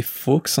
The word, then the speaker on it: Fawkes: c'est Fox en anglais, F A W K E S que j'aurais prononcé pas du tout comme ça.